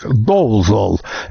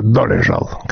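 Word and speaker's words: Dolezal: Кстати, имя, которое американцы произносят здесь как Долзол, это, несомненно, чешское Долежал.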